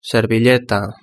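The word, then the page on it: servilleta: Spanish (Spain)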